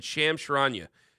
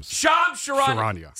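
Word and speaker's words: Shams Charania: And Sham Sharania is going to join us at 8:20 this morning from the Athletic NBA Insider. – Sham Sharania.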